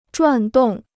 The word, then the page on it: 转动: zhuàn dòng